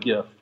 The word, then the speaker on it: GIF: I'm a GIF. I'm a hard G guy.